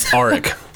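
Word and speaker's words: Auric: And his next mission is to take on Fort Knox, where Eric, spelled with a U, Arik.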